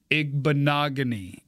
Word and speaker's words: Igbinoghene: Although, if I could just change my own rules of the game, I'd rather put Auburn's Noah Igbinoghene. – You got it. – I'd rather put him in there. – Rhymes with mahogany.